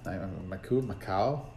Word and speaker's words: Macau: Macau. Macau. – M-A-C-A-U.